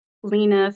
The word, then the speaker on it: Lena: My Instagram is Lena Phillips Art. And it's spelled L-E-N-A.